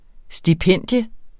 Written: STIPENDIETS

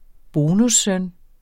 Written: bonussøn

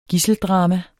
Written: gidseldrama

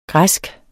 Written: græsk

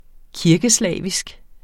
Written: kirkeslavisk

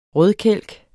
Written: rødkælk

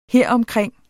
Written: heromkring

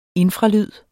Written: infralyd

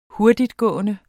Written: hurtigtgående

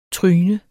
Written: tryne

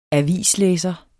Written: avislæser

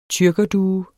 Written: tyrkerdue

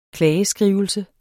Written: klageskrivelse

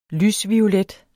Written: lysviolet

